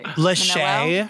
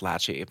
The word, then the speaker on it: Lachey: Lachey. Her last name is Lachey. – I think it's pronounced Lachey, but it's fine.